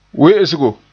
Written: we'esgo

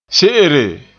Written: se'ere

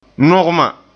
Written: nugma

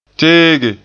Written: teege

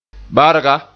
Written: barga

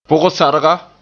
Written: pug-sarga

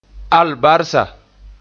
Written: albarsa

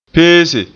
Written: pese

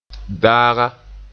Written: daaga

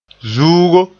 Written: zuugo

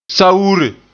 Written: sawure

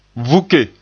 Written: vuke

